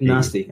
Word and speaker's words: Nasti: Nasti.